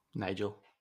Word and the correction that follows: Niger: It's actually Niger.